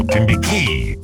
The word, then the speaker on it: Timbiquí: By an outfit called Canelon de Timbiquí. Emphasis on the last syllable, Timbiquí.